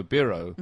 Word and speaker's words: Biro: So this was by the Laszlo Biro inventor himself. – Was he called Biro? – I think it's originally pronounced Biro. – We've been saying it wrong all these years.